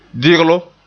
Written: diglum

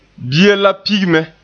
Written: pigi

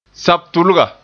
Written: sabtulga

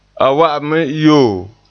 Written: yo

